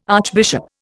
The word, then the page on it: archbishop: Indian